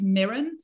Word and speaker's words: Muireann: My name's Mirren, which is spelled M-U-I-R-E-A-N-N. Irish people listening will pronounce it Warren.